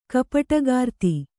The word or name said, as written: ಕಪಟಗಾರ್ತಿ